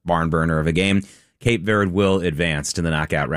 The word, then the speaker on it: Cape Verde: Cape Verde beating, and that is the way you pronounce it. It's Cabo Verde in Spanish, but according to all the sites I looked at in English, you're supposed to say Cape Verde.